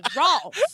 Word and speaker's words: Rolf: Like round the O, Rolf. Say raw.